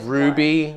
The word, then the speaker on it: Rwbe: His name is Ruby, but it's spelled in a very funny way. – Okay. So... – R-W-B-E.